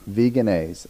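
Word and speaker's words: Vegenaise: Veganaise? Veganaise. Okay. I think it's like mayonnaise, but vegan.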